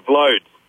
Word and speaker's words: Vloed: They couldn't pronounce my surname, so they just pronounced the first five letters. – What's that? Vlod? – V-L-O-E-D, Vlod.